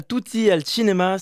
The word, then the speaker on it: Tutti al Cinema: Vous êtes co-organisateur du, festival Tutti al Cinema, si je prononce bien. – Très très bien prononcé.